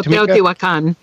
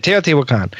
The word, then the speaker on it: Teotihuacan: So it also led to the decline of the city of oh my gosh, Teotihuacan. I can't say that right. You can. – Oh, Teotihuacan. That's it, Teotihuacan. Yeah.